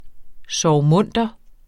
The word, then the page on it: sorgmunter: sɒɐ̯wˈmɔnˀdʌ; ˈsɒːwˌmɔnˀdʌ